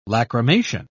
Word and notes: lacrimation: British English

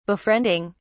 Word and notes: befriending: British English

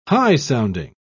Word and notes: high-sounding: British English

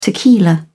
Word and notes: tequila: British English

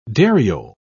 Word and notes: dariole: British English

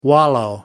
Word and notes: wallow: American English

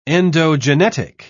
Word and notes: endogenetic: British English

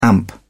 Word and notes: amp: British English

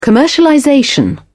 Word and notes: commercialization: British English

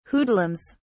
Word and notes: hoodlums: British English

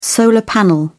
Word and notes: solar panel: British English

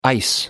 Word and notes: ice: American English

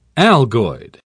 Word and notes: algoid: British English

